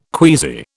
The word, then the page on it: queasy: British English